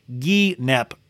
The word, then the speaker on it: guinep: She goes, it's pronounced guinep.